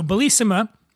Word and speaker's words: Bellissima: So Bellissima, I'm saying that right? Yeah. Right? Yes.